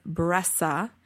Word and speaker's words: Bressay: I have learned that the pronunciation of this pattern is Bressa, even though it's spelled B-R-E-S-S-A-Y.